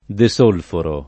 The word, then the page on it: desolforare: lingua italiana